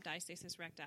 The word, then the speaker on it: diastasis recti: So if you look up diastasis recti, I hope I'm saying that right.